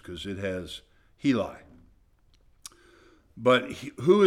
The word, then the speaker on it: Heli: Here it has H-E with the long vowel sound, and then L-I with the long vowel sound and a hyphen between H-E and L-I, so it'd be Heli. But when you, for the first time ever, I got four pronunciations that were exactly the same, and they say Hela.